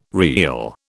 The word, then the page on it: reel: British English